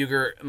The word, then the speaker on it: Uyghur: It's pronounced Uyghur. We were corrected,